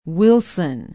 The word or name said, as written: wilson